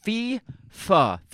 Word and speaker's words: FIFA: Google says FIFA. Yes. F-E-E-F-U-H.